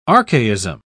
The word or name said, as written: archaism